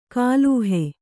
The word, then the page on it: ಗ್ಲಹ: glaha